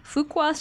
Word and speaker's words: Fuqua: Duke University's Fuqua School of Business. Did I say it right? Yeah. – Yes.